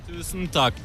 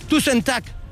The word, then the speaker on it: Tusen takk: Tusen takk. – Tusen takk. – Yes. – Bueno, no sé si lo he dicho, pero tú sentac.